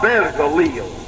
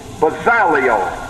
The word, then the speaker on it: Bezaleel: Make that a four-syllable word, please. B-E-Z-A-L-E-E-L. Four syllables. Of course, there's no absolute rule on how to pronounce these words. My self-pronouncing Bible breaks it up into four syllables. Not Bezaliel, which would be three syllables, but four syllables.